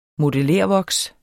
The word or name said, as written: modellervoks